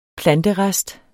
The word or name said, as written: planterest